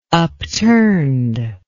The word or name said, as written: upturned